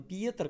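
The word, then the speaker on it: Pieter: Этот человек, ну, этот, собственно, Питер, да, но он, правда, не Питер, он Пьетер, как-то так пишется, наверное, как-то по-другому читается, но я называю его про себя Питер.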